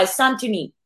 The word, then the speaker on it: Santoni: How do we actually say your name properly? – You know what? Just go listen to how Nico says it. He says it better than I can say it, but I'll try. Santoni.